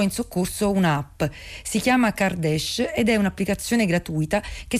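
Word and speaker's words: Kardeş: Kardesh, che si scrive Kardes con la K iniziale, ha un doppio significato.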